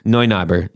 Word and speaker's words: Neunaber: It's spelled like N-E-U. – N-E-U. – Yeah, it's Neu. – Neunaber.